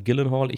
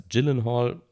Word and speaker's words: Gyllenhaal: Manche sagen Gyllenhaal. Ich habe lange Gyllenhaal gesagt, bis ich dann in vielen Interviews gehört habe, dass er als Gyllenhaal ausgesprochen wird. Ich glaube, ursprünglich da wo sein Name eigentlich herkommt, hat er mal in, in einem Interview hat er mal scherzhaft erklärt, dass man Jelenhall sagt oder so.